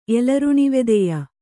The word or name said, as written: ಎಲರುಣಿವೆದೆಯ